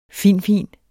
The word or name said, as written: fin-fin